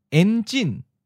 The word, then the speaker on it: enjin: Enjin. En is in the ninth tone. Enjin is in the second tone. High falling.